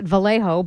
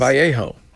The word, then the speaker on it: Vallejo: Evidently the locals call it Vallejo, but I just went with the Spanish pronunciation of Vallejo, so my apologies. – Vallejo.